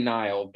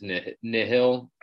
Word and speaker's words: Nihil: We say Nile, but I, I think the the pro- proper pronunciation is is nihil.